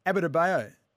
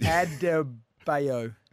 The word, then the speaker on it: Adebayo: Gabe Vincent scored 23 points for the Heat, while Jimmy Butler and Bam Adebayo. – Pardon? – What is it? How's it spelled? Say it again. Because you just. – Adebayo.